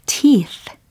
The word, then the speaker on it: teeth: teeth. 끝부분에 번데기 발음이라고 하죠. teeth. teeth 해보시고요.